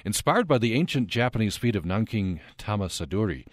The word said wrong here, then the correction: Tamasudare: And I don't know if I'm pronouncing that correctly. – Tamasudare. – Tamasudare. Okay.